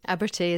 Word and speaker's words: Abertay: You've received an honorary doctorate by University of Edinburgh, 3 honorary degrees from Aberdeen, Glasgow, and if I pronounce it right, Abertay is in Dundee, yeah, and Stirling.